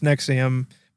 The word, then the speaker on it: NXIVM: Yeah, NXIVM is how you spell Nexium. It's pronounced Nexium, but Uncover, I think, is the name of the podcast.